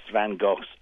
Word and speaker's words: Van Gogh: I've seen, like, countless Van Goghs over the years. You must forgive me pronouncing it the English way.